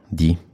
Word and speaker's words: Die: Wracając już z Complaval, nie tą samą drogą, tylko jadąc dalej, mm, w sumie taką pętlę zrobiliśmy tego dnia, przejeżdżaliśmy przez miasteczko Di. Pisze się Die, po trzy literki.